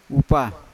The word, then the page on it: upa: Yawa